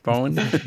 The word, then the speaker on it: Borgen: I mean, we have great tradition producing a TV drama, and Borgen or Bone is actually one of the biggest successes. Yeah, Bone. – Yeah, completely butchered that one.